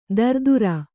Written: ದರದುರ

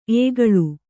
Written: ಎಕ್ಕಲ

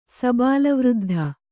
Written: ಸಬಾಳವೃದ್ಧ

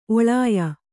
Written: ಒಳಾಯ

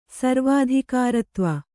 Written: ಸರ್ವಾಧಿಕಾರತ್ವ

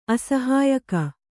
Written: ಅಸಹಾಯಕ

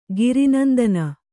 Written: ಗಿರಿನಂದನ

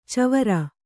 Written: ಚವರ

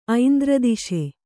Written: ಐಂದ್ರದಿಶೆ

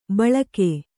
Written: ಬಳಾಕೆ

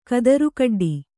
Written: ಕದರುಕಡ್ಡಿ

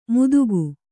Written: ಮುದುಕು

